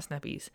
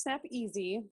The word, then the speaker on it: Snap Easy: It's not Snap Easy. Snappies. It's not Snappies. It's Snap Easy. Like E-A-S-Y. Anyways, she'll go over that with you. – So it's Snap Easy, which, and I will tell you the backstory.